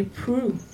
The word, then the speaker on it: Proulx: Det skrives P-R-O-U-L-X. Så det er ikke, jeg syns ikke det var selvforklarende at det uttales pru, men det gjør det.